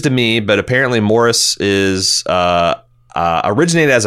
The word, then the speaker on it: Maurice: Based on the pronunciation guide, Maurice Picard uses the English favored pronunciation of the name Maurice, a.k.a. Morris.